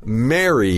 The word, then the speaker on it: marry: Marry is the verb. – M-A-R-R-Y, right? But it's the same pronunciation as the name Mary.、I married Mary.